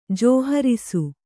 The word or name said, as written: ಜೋಹರಿಸು